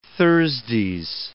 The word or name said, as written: Thursdays